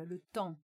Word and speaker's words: le temps: pois é, é, por exemplo, uma outra, um outro, uma outra palavra, le temps, o tempo com uma sílaba e cinco letras, das quais as duas últimas, P e S, são mudas.